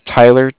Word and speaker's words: Tyler: And like I said, my name is Tyler, T-Y-L-E-R. And I'm calling with Cardiff. It's spelled C-A-R-D-I-F-F, as in Frank Frank.